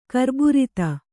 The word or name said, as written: ಕರ್ಬುರಿತ